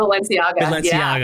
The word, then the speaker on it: Balenciaga: Balenciaga, yeah.